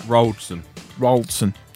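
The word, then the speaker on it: Wroldsen: Is that Worldson? Roldson. How would you pronounce that? – Roldson.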